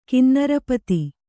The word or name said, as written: ಕಿನ್ನರಪತಿ